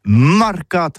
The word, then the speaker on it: mercat: Eh bien, disons à tous, mais le mercat de l'Uni ou le mercat des rebelles, mais en gascogne toulousano, c'est prononcé sur les marcat, avec un A marquette.